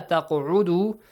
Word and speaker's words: تَقْعُدُوا: أَنْ إِذَا سَمِعْتُمْ آيَاتِ اللَّهِ يُكْفَرُ بِهَا وَيُسْتَهْزَأُ بِهَا فَلَا تَقْعُدُوا. تَقْعُدُوا, the letter qaf as well, is pronounced with an echoing sound.